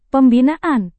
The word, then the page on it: pembinaan: Indonesian